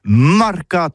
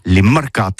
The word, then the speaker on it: marcats: Mais en gascogne toulousaine, c'est prononcé sur les marcats, avec un A.